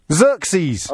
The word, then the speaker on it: Xerxes: Yeah, but it's Z. Xerxes. – Oh, is that That's how you pronounce X at the beginning of a word. Unless it's X-ray.